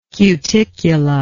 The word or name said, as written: cuticula